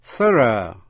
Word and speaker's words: thorough: Còn có chữ T-H-O-R-O-U-G-H. UGH có hai cách đọc, thorough theo người Anh, thorough theo người Mỹ, nghĩa là tỉ mỉ, kỹ càng.